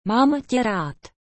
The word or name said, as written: mam te rad